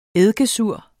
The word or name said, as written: eddikesur